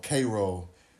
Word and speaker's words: Cairo: called Cairo. It's like Cairo, but spelled the same, but pronounced differently, which was a prominent town on the Mississippi River and, you know, and had a lot of things going for it.